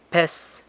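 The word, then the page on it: pes: San Lucas Quiaviní Zapotec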